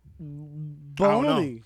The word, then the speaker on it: bologna: First of all, I knew never to trust bologna because of the way it's spelled. That's all I said. – Like lasagna. – Yeah. I'm like, that shit's silent. – Whatever. – Like, got a G and everything in that motherfucker.